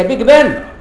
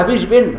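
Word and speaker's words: Big Ben: ساعات بيق بن كده، اعلانات كبيره قوي عندكم بيخ بن، هي بيج بن، انما لو انه كتبها بالجيم المصريه هنتقوها بيش بين غلط.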